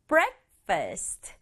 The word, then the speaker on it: breakfast: Breakfast. 이 A가 에 소리 나요. 그래서 breakfast. First, 강조는 앞에 breakfast, 두 박자.